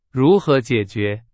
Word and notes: 如何: Chinese

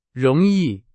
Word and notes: 容易: Chinese

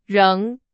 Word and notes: 仍: Chinese

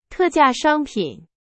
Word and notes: 特价: Chinese

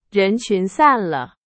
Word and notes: 散: Chinese

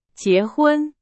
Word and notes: 结婚: Chinese

